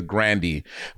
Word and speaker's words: Grande: Her last name is not Grande, it's Grandy.